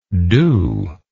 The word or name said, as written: dueness